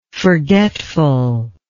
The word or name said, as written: forgetfulness